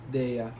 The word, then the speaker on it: Dehya: Deya. – How do you spell that? – D-E-H-Y-A. Deya. – D-E-H-Y-A.